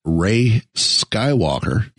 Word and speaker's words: Rey Skywalker: They announced the Black Series Rey Skywalker.